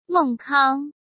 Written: Meng Kang

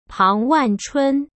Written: Pang Wanchun

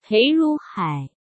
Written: Pei Ruhai

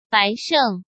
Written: Bai Sheng

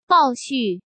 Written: Bao Xu

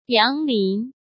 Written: Yang Lin

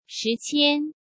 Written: Shi Qian

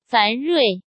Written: Fan Rui

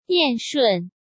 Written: Yan Shun

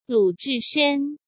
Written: Lu Zhishen

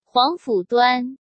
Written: Huangfu Duan

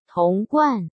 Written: Tong Guan

